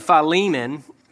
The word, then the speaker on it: Philemon: which is the right way to say Philemon, but he writes to Philemon regarding his escaped slave Onesimus.